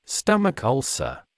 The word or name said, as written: stomach ulcer